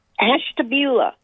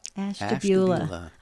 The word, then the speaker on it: Ashtabula: I always mess up your city when I pronounce it. Would you pronounce it for us? – Ashtabula. – Ashtabula. I love that. It doesn't quite roll out off your tongue, but it's close.